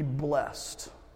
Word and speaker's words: blessed: A pet peeve of mine is, for some reason, when you read the Bible, you want to pronounce this word blessed. I'm not sure why. We want to add that second syllable there, but it is a blessed man, a blessed man.